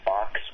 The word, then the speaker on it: Bochs: I think the first one that I used was called Fox, which is spelled B-O-C-H-S, and it appears that they still have this project alive and well as of 2 months ago.